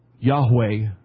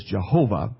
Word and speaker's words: Yahweh: Now sometimes the Old Testament in the King James sometimes uses a word that we pronounce as Jehovah, but if you look at the actual Hebrew text, the more accurate pronunciation of that which is a letter for letter and sound for sound, would be Yahweh.